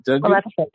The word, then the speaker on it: Maleficent: I think, is it Maleficent? Is that right? – Maleficent.